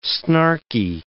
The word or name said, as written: snarky